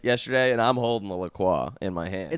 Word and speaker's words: La Croix: So, anyways, we're talking about it as we're going, and I hear from behind me, it's La Croix.